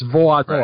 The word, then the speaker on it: voador: The best way to put it is the L is silent, so it's carajo, And then in English, VOA would be one syllable, but in Portuguese it's two. So it's voador.